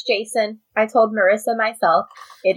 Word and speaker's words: Jacin: I do remember hearing her say that it's pronounced Jason.